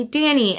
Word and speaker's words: Dannie: So I thought, ooh, Ashley Danny. And I've always wanted to spell it differently. It's not D-A-N-N-Y. It is D-A-N-N-I-E.